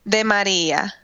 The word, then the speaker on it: Di Maria: So what is the proper Italian pronunciation? – De Maria. – De Maria. Okay, I was pretty close. – You rolled your R.